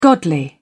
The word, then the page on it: godly: British English